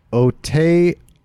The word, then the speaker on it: Aotearoa: Though Nick provided a valiant effort in his pronunciation, it's pronounced, oh, here we go, Aotearoa.